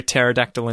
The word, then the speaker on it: pterodactyl: Why can't you hear a pterodactyl in the bathroom? Because it has a silent P.